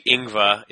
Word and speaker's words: Ingva: My middle name is a bit difficult for most people to pronounce if they just see it on paper, but it's actually Ingva. It's a German middle name.